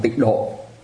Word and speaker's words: tịnh độ: Thường thường mình đọc là tịnh độ, chứ mình không nói là tịnh thổ, nhưng mà viết chữ tàu ra thì là chữ tịnh thổ, tức là cái đất thanh tịnh.